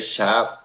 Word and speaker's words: Kjær: I don't know how to – is it Schaff is how you say that? – I say Schaff because I'm Scandinavian.